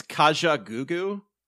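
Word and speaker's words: Kajagoogoo: So is it Kaga Juju? – I think it's Kaja Gugu, which isn't any Kaja better.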